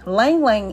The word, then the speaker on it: Ylang Ylang: Ylang Ylang is spelled Y-L-A-N-G, and it's pronounced Ylang Ylang, L-A-N-G.